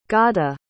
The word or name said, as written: gada